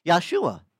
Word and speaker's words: Yahshua: Because he had a name, Yahshua. We pronounce it Joshua, but it's really in Hebrew, Yahshua.